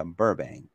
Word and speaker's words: Burbank: Burbank, o Burbank, se pronuncia Burbank.